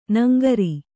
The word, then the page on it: ನಾಂಗರು: nāŋgaru